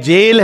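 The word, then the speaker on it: gaol: तो यहां पर उसका वर्णन है, ए जेल is this immense material world, ये पूरी जो भौतिक जगत है एक जेल है। इसमें स्पेलिंग कुछ लोग देख रहे होंगे, जी ए O L, but it's pronounced as जेल, इसमें दोनों जे ए आई एल भी होता है, जी ए ओ एल भी होता है, जेल is this immense material world.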